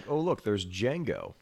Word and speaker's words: Django: And he goes, and there's Django, and he goes D J A N G O. The D is silent.